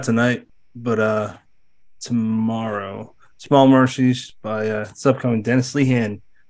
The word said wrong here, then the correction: Lehane: Dennis Lehane.